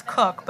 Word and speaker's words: Koch: It's K Koch, but it's Cook, but it's K O C H is my last name.